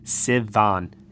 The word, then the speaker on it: Sivan: I hope I'm saying his name right. Sivan. Uh, mm-hmm. Troye Sivan. I think I think that's how you say it. – It is.